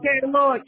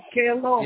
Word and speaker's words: Kerelaw: Carelo Residential School. – Carelo, sorry, do you know how that's spelled? – Carelo, K-E-R-E-L-A-W, Carelo.